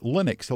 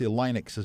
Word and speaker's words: Linux: But it's usually pronounced Linux, although I've heard people say Linux as well. – He is a Finnish. Yeah, Swedish-speaking Finn. So yeah, he pronounces his name Linus. So I guess if you want to be pedantic, you could call it Linux.